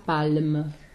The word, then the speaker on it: palme: So you have to say the L. – Oui. – And the exactement. Same thing with... – Berce sa palme. – Yeah, so the L is actually pronounced.